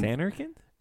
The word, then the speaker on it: Zanarkand: He's from Dream Zarkan Land. I, am going to say it wrong each time. So there's a real Zarkan Land way back in the day, and Zanarkand. Zanarkand. Jeez.